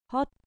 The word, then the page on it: hot: grec